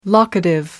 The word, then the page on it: locative: American English